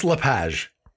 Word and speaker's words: Lapage: It's Lapage.